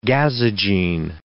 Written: gazogene

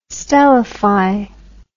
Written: stellify

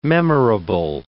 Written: memorable

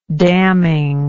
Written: damningness

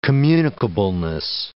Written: communicableness

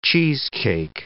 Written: cheesecake

